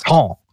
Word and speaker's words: Bertin: I'll just go by tier up until tier 5, the Emile Bertin, I believe is how it would be pronounced. – Bertin.